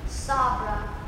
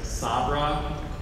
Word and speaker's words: Sabra: Sabra. Are there any other alternate pronunciations? – There's Sabra and Sabra.